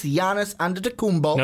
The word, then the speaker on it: Giannis Antetokounmpo: See, I didn't do the hard G. Yep. I didn't give you the Giannis. No, it's Giannis Antetokounmpo.